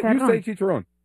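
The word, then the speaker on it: chicharrón: Chicharron. – Ooh, it's good. – There you go. Okay. – Did I say it right? – No, you say chicharron.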